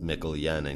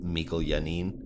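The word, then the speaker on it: Janín: Yeah, that's when uh, Mikkel Yanin told me to pronounce it Mikkel Yenin because I was pronouncing it Mikkel Yannin.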